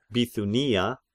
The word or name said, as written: Bithynia